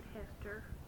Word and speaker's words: Hester: Helen Basket Hester, H-E-L-E-N, B-A-S-K-E-T-T, H-E-S-T-E-R.